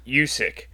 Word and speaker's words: Usyk: Uh, now I'm just saying it wrong. Usyk.